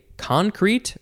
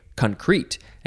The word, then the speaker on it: concrete: Now, you can say concrete, emphasize the first syllable, or you can say concrete, and it really depends on the form.